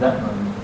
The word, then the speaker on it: Zetman: Prabhupada doesn't say the J, he pronounced it Jetman. And he didn't say the Z. It was Lord Zetman. Is the correct pronunciation. And Prabhupada said, Lord Jetman. That's how he pronounced Zetman.